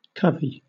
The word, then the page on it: covey: British English